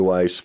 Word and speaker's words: Weiss: The last name is spelled W-E-I-S-S. LinkedIn, it's Kenny Weiss.